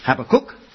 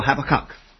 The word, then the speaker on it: Habakkuk: There was one disagreement, and that is, how do you pronounce Habakkuk? Is it Habakkuk or Habakkuk? Now, since I'm from the London area, I should probably say at the end, uck, but I hope that won't offend you.